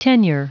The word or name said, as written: tenure